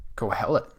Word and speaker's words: Qohelet: What else he talked about, Ecclesiastes and the author of Ecclesiastes, and Qohelet, I think is how. – Qohelet, okay. – I've heard it pronounced.